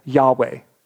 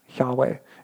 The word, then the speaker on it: Yahweh: But most modern scholars believe that it was probably pronounced instead of Jehovah as Yahweh, Yahweh. And it had more of a breathy sound.